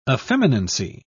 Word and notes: effeminacy: British English

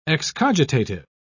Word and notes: excogitative: British English

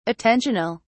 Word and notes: attentional: British English

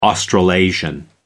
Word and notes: australasian: American English

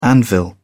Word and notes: anvil: British English

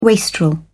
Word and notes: wastrel: British English

